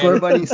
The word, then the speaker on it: Scorbunny: It's going to include three new starting Pokemon, which are going to be Scorbunny, Sobble, and Grookey. – Are we pronouncing those right? – Yes, you are so far. – Okay, Scorbunny, Sobble, and Grookey.